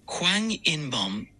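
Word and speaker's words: Hwang In-beom: El nombre Hwang In-bom en coreano se pronuncia de la siguiente manera. Transcripción fonética en coreano es Hwang In-bom. Pronunciación aproximada en español, Hwang In-bom.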